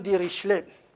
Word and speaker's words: Dirichlet: Et le jeune de, de Richelette, prononcé, germanisé, ça devient le jeune Dirichlet avec un ch allemand.